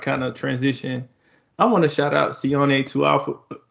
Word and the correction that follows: Taufo: who I always butcher that. John, help me here. – Taufo. – Taufo. I'm about to say Tuafo.